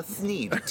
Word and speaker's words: thneed: By the way, he doesn't have a lisp. It's just the way it's pronounced. – No, it's called a thneed. T-H-N-E-D. – Say it again. – Thneed. – Okay. – It's Dr. Seuss's word.